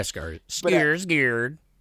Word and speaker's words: Skarsgård: How do you say his name? Skarsgård? – Yeah, Skarsgård.